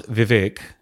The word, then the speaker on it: Vivek: So it's pronounced Vivek in Sanskrit and it actually means wisdom.